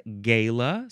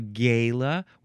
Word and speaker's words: gala: You can pronounce it a couple other ways. Gala. Some people like to say that. Maybe a gala where people are acting gay and happy and having a very good time, dressing up, partying, dancing. You can also pronounce it gala. That is obviously the most fancy way to say this word. Gala, gala, gala. G-A-L-A, noun from 1777.